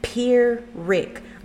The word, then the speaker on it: Pyrrhic: The word Pyrrhic is spelled P-Y-R-R-H-I-C, and it's pronounced Pyrrhic, like P-ear, as in the ear you hear with Rick in the name. So Pyrrhic, Pyrrhic victory.